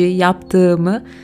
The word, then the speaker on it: yaptığımı: But instead of saying yaptım, you say yaptığımı with the soft G, yaptığımı.